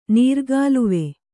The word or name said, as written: ನೀರುಕಾಲುವೆ